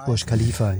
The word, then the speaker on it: Burj Khalifa: Klar, also diese klassischen Glasfassaden, wo die sich dann oben abseilen und dann permanent Burj Al Khalifa oder wie das höchste Gebäude der Welt, ich hoffe, ich habe es richtig ausgesprochen. – Burj Khalifa, ja.